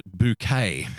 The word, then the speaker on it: Bucket: And the matriarch of this show is a woman named Mrs. Bucket, spelt B U C K E T. But of course, she pronounces it bouquet. I'm Mrs. Bouquet.